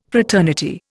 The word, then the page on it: fraternity: Indian